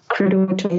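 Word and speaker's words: Credo Mutwa: I've heard you guys talk about the Credo Mutua book, and forgive me if I'm mispronouncing that. – Credo Mutua, yes.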